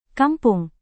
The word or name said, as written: kampung